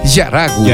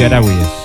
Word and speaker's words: Harawi: No, no, la china dice que es Yaragui. – Yaragui, sí. Se escribe Harawi y se pronuncia Yaragui.